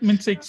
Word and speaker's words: MINT-seq: For that, you developed a new method called MINT-seq, or how you pronounce it. – Yep, MINT-seq.